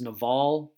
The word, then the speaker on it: Naval: So there was, there's a guy, and I believe is how you say it is Naval, but it's NAVAL.